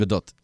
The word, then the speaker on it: Gadot: And how do you say her last name? – Gadot. – Very nicely done. Very Hebrew of you.